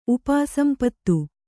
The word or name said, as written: ಉಪಾಸಂಪತ್ತು